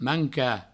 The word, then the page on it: mancar: manca